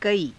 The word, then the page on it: kaii: Hindi